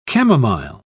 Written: chamomile

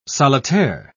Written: solitaire

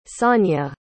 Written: sanja

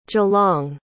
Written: geelong

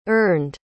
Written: earned

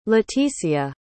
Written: leticia

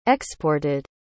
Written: exported

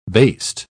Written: baste

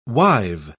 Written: wive

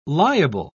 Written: liable

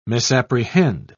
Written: misapprehend

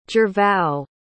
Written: gervao

